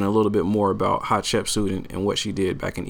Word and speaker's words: Hatshepsut: Some more insight that you have is a book that you wrote, The Woman Who Would Be King, in Hatshepsut. Am I saying it right? – You said it right, yeah.